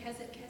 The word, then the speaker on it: Keswick: Keswick. – Keswick. – Yeah, they don't pronounce the W.